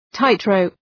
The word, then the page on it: tightrope: ‘taıt,rəʋp